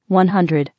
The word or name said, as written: 100